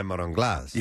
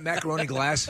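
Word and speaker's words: marron glacé: I said it wrong, didn't I? – Can I have some of that macaroni glass? – All right.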